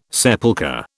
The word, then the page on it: sepulchre: British English